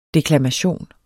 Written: DEKLAMATION

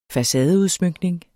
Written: facadeudsmykning

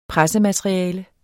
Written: pressemateriale

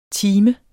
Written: timer

